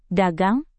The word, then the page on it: dagang: Indonesian